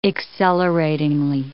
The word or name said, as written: acceleratingly